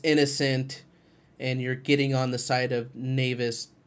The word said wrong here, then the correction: Navin: right away, or Navin. God, I keep saying his name wrong.